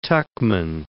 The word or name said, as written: Tuchman